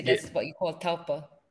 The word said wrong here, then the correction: tulpa: Not taupa. I think they can also call it tulpa.